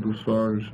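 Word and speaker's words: Bussard: It's B-U-S-S-A-R-D, you know, French, and Buzzard.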